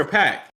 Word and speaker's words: Paak: Is it Pac or Pack? – It's Pac. – If you keep saying Pack, we'll put you in a pack. – Nigga, please. I've never heard somebody say Pac, but it's Anderson .Paak.